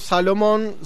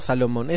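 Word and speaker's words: Solomon: Bueno, así es como Solomón nos dice, no Salomón, esa es nuestra ganas de pronunciarlo de ese modo.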